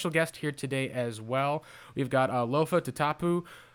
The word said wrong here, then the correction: Tatupu: Is that how you pronounce it, at least for your last name, Lofa? – Close enough. – Tatupu. – It's close though. – Tatupu.